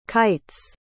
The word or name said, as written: kites